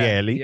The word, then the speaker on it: Geli: It's spelled G-E-L-I. That's her first name. – Gelly? Gelly, yeah. – Gelly. – Yeah, and her last name is R-A-U-B-A-L.